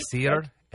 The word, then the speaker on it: Yasser Arafat: And born on this date in 1929, Yasser Arafat. Did I say that right? – Yasser Arafat.